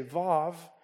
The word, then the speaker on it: vav: One teaching that you ought to pronounce it the way people today would pronounce it and say vav.